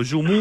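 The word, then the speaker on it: joumou: yomú creo que se llama, y que es una sopa de libertad. – Se escribe houmou, como yomú, sí.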